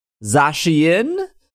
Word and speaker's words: Zacian: We have uh, actual pronunciations on this Zacian and Zamazenta uh,